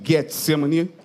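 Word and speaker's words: Getsêmani: Diz assim: e foram a um lugar chamado Getsemane, ou Getsemane, a pronúncia correta, e disse aos seus discípulos: assentem aqui enquanto eu oro.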